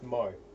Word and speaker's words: Maui: I mean, people from Maui have very short attention spans, so that's fine. – Hey, pronounced Mo. – Mo, sorry. – Fuck's sake. Pronounce things right.